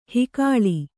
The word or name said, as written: ಹುಗುಳು